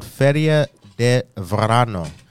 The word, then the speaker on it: Feria de Verano: The date just got changed, I think, to June 10th, but it's called Feria de Verano.